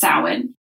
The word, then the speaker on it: Samhain: The people will read it S-A-M and be like, what's Samhain? It's actually pronounced Samhain.